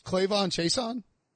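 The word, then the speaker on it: K'Lavon Chaisson: Wait, so it actually rhymes, Clavon Chason? – Yeah, that's easy. Okay, because it's K apostrophe L A V O N.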